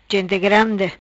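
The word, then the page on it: Gente Grandi: patua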